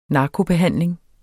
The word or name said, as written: narkobehandling